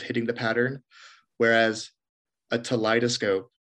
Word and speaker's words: Teleidoscope: So a Teleidoscope is similar to the word that it rhymes with, which I think people are more familiar with, a kaleidoscope.